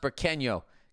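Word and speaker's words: Burqueño: It's pronounced Burqueno.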